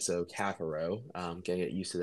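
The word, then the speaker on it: Caffaro: Oh, I know he just changed the pronunciation because everyone was going crazy about it. – Cafaro. – Cafaro, not Cafaro, Cafaro. But he did tweet out saying, whichever way you say it, it does not offend him.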